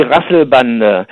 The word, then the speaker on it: Rasselbande: Yeah, in German it means, well, you pronounce it die Rasselbande.